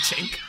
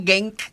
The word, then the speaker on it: Genk: Y yo pensé que se decía Genk. – Ajá, por eso, yo también decía Genk. No, déjame chequearlo. – Yo miro a esto y me dice, estoy corroborando la pronunciación. – Chenk. Ok, perfecto, gracias. Y así mismo lo tengo escrito, Chenk.